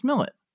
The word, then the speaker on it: Millett: I'm your host, and with me today for a little bit different episode, I have Chris Millett.